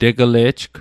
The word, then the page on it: tegele'jg: Mi'gmaq